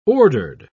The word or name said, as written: ordered